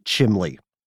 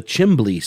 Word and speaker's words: chimney: Yeah, chimbley is a legitimate sort of dialectical variation of chimney.